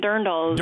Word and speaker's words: dirndls: If you got lederhosen or, how do you pronounce it, dirndls? Is that how you pronounce that? – Yeah, lederhosen and dirndls. – Dirndls, okay, there you go.